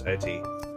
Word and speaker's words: Eti: um, so yeah that, that that's what I have to say about Pinchu and Eti. By the way, Eti is spelled uh, E T I, but it's pronounced as E T E E.